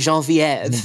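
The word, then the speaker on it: Geneviève: you would pronounce it Jean Vieve, Jean- which Genevieve to be endearing the entire time.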